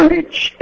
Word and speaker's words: leet: Chama LIT, L-E-E-T.